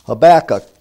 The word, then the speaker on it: Habakkuk: But we'll turn our Bibles to the little book of Habakkuk, better pronounced Habakkuk.